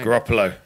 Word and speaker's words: Garoppolo: Garoppolo.